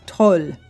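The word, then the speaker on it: Tolv: It is the Toll. Now, Swedish folks, if I got that wrong, because it's T-O-L-V, if I got that wrong, please feel free to email me and correct me, because sometimes I'm not so good at that.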